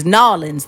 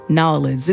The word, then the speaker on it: New Orleans: Do you plan on going to New Orleans? You know it's going to be hot. Actually, it's Nawlins. That's how you say it. Nawlins.